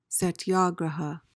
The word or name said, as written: satyagraha